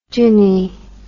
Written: gyny

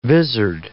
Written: vizard

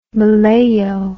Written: malayo

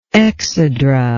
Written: exedral

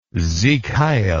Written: sieg heil

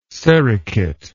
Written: sericate

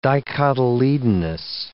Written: dicotyledonous

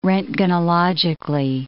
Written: roentgenologically

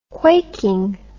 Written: quaking

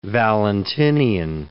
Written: Valentinian

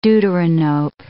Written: deuteranope

